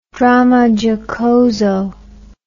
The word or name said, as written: dramma giocoso